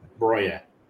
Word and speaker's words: Broja: How do you pronounce it, by the way? – Broja. Let's say Broja.